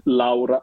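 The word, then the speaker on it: Lára: il cui nome si scrive Lara con l'accento, però là con l'accento in islandese si legge Au, quindi Laura.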